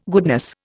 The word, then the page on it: goodness: Indian